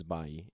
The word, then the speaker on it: Bailly: His name is Eric Bailly. – It's Bailly.